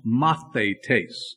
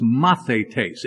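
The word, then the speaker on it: mathetes (μαθητής): The Greek word is pronounced mathetes. It's there in your notes. The G-R means Greek, not great, or some other G-R. It's Greek, and it's mathetes.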